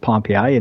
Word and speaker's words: Pompeii: We have a small town nearby that's called Pompeii, spelled Pompeii, but it's pronounced Pompeii in Michigan.